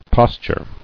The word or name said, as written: Posturing